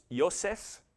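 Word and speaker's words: Yosef (יוסף): This is Joseph, would have been pronounced Yosef, which means to add.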